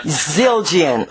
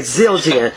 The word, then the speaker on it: Zildjian: Zildjian. – Okay, Zildjian.